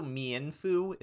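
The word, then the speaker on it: Mienfoo: Well, or it's also Mianfu is another pronunciation. Its name is supposed to be Ermine and Kung Fu.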